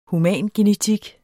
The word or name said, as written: humangenetik